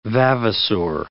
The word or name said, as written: vavasour